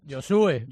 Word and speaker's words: Josué: Josué. – ¿Con acento en la U, ¿no? – Eso dice. – Vale, pues en internet viene Josué, con acento en la U.